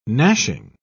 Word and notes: gnashing: British English

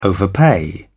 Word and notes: overpay: British English

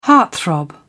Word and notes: heartthrob: British English

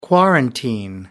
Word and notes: quarantine: American English

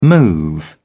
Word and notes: move out: British English

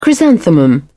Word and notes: chrysanthemum: British English

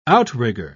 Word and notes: outrigger: British English

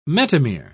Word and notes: metamere: British English

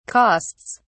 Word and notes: costs: British English

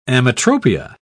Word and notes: ametropia: British English

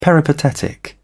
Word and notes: peripatetic: British English

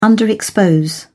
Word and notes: underexpose: British English

